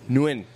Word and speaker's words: Nguyen: Nguyen. – Yeah. – It's like Illinois. – Yeah. – He's silent. Okay, Cody Nguyen, 287, says, how often does a homicide case become a cold case, and how do you guys handle it?